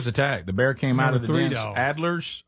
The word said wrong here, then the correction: alder: Or alder? – Yeah. Yeah, same. Spelled just like your name, but maybe it's pronounced Alder.